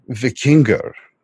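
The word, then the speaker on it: víkingur: And what you are saying about Vikings, the word vikingur is the, the proper pronunciation of it.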